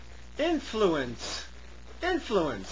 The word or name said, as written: influence